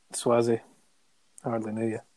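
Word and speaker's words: Sauzée: Frank Sozzi. – Swazzy, was it? – Swazzy, you're right. Been saying his name wrong the whole time.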